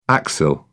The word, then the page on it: axil: British English